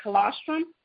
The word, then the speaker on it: colostrum: So I did see the highlight of something that you had referred was the essential flora, and it, I don't know how you say it, is the colostrum? – Right.